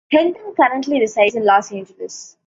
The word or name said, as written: resides